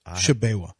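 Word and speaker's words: Tshiebwe: How do you pronounce his last name? – Shabewa.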